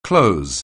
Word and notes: close: anglais

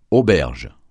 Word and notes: auberge: francais

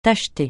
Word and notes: tacheter: francais

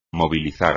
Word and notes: movilizar: espagnol

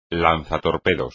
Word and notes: lanzatorpedos: espagnol

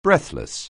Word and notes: breathless: anglais

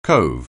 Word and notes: cove: anglais

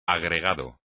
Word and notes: agregado: espagnol

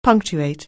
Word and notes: punctuate: anglais

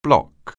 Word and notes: block: anglais

